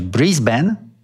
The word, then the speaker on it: Brisbane: Otóż tubylcy szkolą na początku pobytu turystów, aby wymawiać Brisbane lub Bris Vegas. Zawsze w ten sposób rozpoznają obcych.